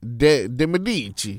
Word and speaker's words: de Medici: De Medici. You got to say it like that.